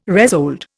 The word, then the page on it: resold: Indian